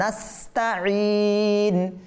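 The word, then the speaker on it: নাস্তাঈন (نستعين): এরপর ওয়া ইয়াকা নাস, নাস সিনটাকে স্পষ্ট করতে হবে, আওয়াজ বের করতে হবে, নাস্তাঈন, ঈন। অনেকে বলে নাস্তাঈন, নাস্তাঈন বললে কি হবে?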